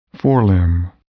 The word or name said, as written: forelimb